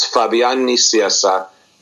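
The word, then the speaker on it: Fabián Nicieza: Yeah, it's not Italian. It's Spanish. And you just pronounced it Italian before, but it's Spanish. It's actually, I made it my pinned tweet on Twitter just to have it there all the time. In Spanish, it's Fabian Nicieza.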